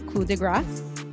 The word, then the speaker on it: coup de grace: The proper pronunciation of it is coup de grace. The reason why it's pronounced coup de grace is because a lot of Americans think that they're pronouncing it the French way by dropping the sound, just like how we don't say the P in coup.